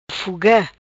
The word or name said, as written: Fuga